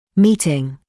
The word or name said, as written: meeting